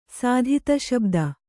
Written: ಸಾಧಿತಶಬ್ದ

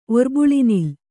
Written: ಒರ್ಬುಳಿನಿಲ್

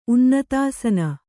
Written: ಉನ್ನತಾಸನ

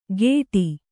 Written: ಗೇಟಿ

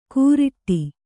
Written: ಕೂರಿಟ್ಟಿ

